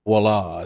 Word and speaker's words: Voila: Voila. Am I pronouncing it right? – Voila. – Voila. Yeah, you see, you're up in Canada where you know these Frenchy words.